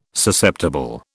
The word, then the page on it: Susceptible: British English